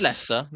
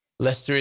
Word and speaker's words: Leicester: Leicester is really hard with people with dyslexia. Okay. – Yeah. It's not pronounced great, is it? It's not written how it's pronounced.